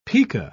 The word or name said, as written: pika